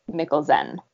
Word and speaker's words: Mikkelsen: Nickel is pronounced just like nickel, and Zen is like Zen, Z-E-N, resulting yeah. in a surname that, if pronounced properly, is Mikkelsen.